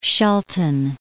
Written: Shelton

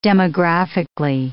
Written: demographically

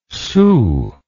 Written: sault

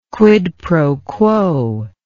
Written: quid pro quo

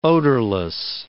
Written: odorless

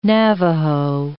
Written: Navaho

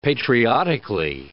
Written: patriotically

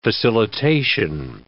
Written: facilitation

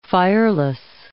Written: fireless

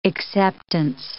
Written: acceptance

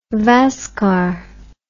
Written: VASCAR

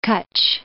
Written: Gulf of Kutch

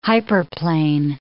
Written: hyperplane

